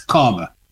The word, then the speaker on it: Caldmore: Walsall town, or should I say a little area in Walsall town called Carmer, which is spelled Caldmore, Caldmore, but, mm-hmm. you know, we Black people pronounce it as Karma, you know, Karma.